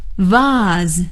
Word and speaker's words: vase: 有的时候朋友们给我们买了鲜花，我们要放在这个花瓶里，花瓶 V-A-S-E, vase, vase, 或者也可以念成 vase, Vase, vase, vase 都是花瓶的意思。